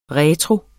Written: retro

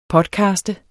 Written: podcaste

